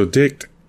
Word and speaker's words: Dict: So Dict is, that's spelled, because I know it may sound funny the way I'm saying it. It's D-I-C-T. And that's short for dictionary.